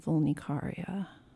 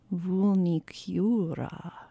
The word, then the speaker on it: Vulnicura: And before that was Bjork with the song Black Lake from the album Vulnicaria. How does she pronounce it? Hang on. Vulnicura.